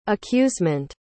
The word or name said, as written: accusement